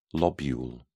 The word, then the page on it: lobule: British English